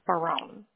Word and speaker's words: Baron: Okay, it's James Barone. – James, okay. How do you spell that last name, please? – B-A-R-O-N. – B-A-R-O-N, okay.